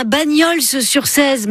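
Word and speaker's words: Bagnols-sur-Cèze: Vous avez un Bagnols sur 16, mais on prononce pas le S.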